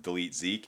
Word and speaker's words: DLTZK: It is stylized D-L-T-Z-K. There are no E's, but it's pronounced Delete Zeke.